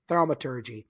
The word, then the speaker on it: thaumaturgy: Thaumaturgy.